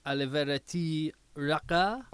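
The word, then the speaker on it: Alivereti Raka: Aliverity Raka. – Raka. – Raka. Yeah, it'll roll the R on it.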